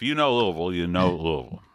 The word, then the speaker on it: Louisville: Louisville. – That's Louisville, not Louisville.